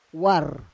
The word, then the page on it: war: Roon